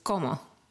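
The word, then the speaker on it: cómo: So the first one is, como. – Como. – Just como. – No, that's how you say it. So like, como. And then put like a question mark on the end.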